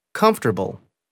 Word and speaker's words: comfortable: The vowel immediately after a stressed syllable is sometimes not pronounced. – Average. – Different. – Separate. – Comfortable.